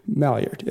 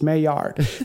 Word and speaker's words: Maillard: See, anytime I've ever talked about this in homebrew club meetings, it's always been Maillard. And then today I was researching how to pronounce it, and it's Maillard.